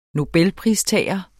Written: nobelpristager

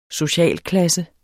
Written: SOCIALKLASSE

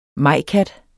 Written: majkat

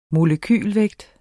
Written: Molekyle

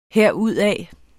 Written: herudad

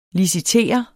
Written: licitere

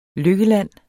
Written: LYKKELAND

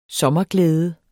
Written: sommerglæde

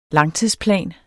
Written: langtidsplan

